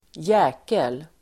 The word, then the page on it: djäkel: ²j'ä:kel